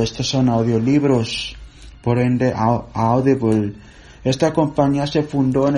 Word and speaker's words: Audible: Se escribe A-U-D-I-B-L-E, Audible.